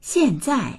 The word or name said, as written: xian4zai4